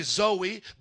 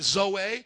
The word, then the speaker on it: Zoe: In the English, we would say Zoe, but it's pronounced in the Greek Zoe.